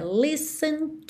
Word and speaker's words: listen: Além da pronúncia dessa palavra listen, que a gente vai ver mais sobre isso na parte 3, mas você já percebeu que esse T não aparece, ok? Então, olha, listen to.